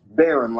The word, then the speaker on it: Baron: Baron, like Baron Davis Baron. Or the Red Baron.